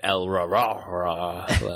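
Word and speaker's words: El-ahrairah: El-Rah-Rah-Rah. I think is how they pronounce yes.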